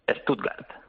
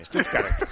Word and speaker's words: Stuttgart: Stuttgart. – Ah, igual. – Sí, igual. – Hay que pronunciar las T's bien. Stuttgart, claro.